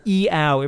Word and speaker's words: Iao: Iao. I-A-O. Iao. Every vowel syllable.